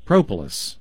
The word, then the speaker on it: propolis: P-R-O-P-O-L-I-S. Propolis. – Okay. – It's almost like the word Polish with a pro on the front of it.